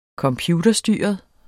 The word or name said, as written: computerstyret